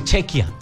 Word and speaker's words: Chequia: Y a República Checa se le dice Chequia.